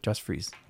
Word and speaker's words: Josh Freese: how do you say his name? – Josh Freese.